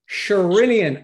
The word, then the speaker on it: Sherinian: Sherinian.